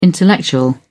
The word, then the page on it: intellectual: British English